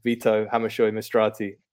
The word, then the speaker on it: Vito Hammershøi-Mistrati: And hopefully I've done justice to your name. I apologise in advance for all Danish pronunciations. But Vito, welcome to the Nordic Football Pod. – Thank you. Thank you. And you did well. Actually, there's a lot of Danish people who can't even pronounce it. So I think you did well.